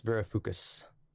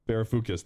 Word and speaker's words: Varoufakis: I think Giannis Ver- uh, I, I, can never pronounce his last name. Right? Yanis Varoufakis. Varoufakis. that's it.